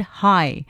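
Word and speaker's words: hej: Roland says, the most common way to say hello in Danish is the H-E-G. How to pronounce it? Well, he says, which is pronounced just like the English word hi.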